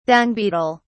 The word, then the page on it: dung beetle: British English